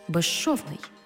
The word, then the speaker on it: безшовний: У словах «безшовний» і «безшумний» – свистячий «з» плюс шиплячий «ши» всередині слова разом вимовляємо як «жи-ши» – «безшовний» – «безшумний».